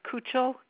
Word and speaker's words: Kuechel: My last name is Kuchel, K-U-E-C-H-E-L.